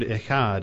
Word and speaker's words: echad: This word that's translated one in this statement is the Hebrew word echad. It's spelled E-C-H-A-D.